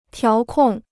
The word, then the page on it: 调控: tiáo kòng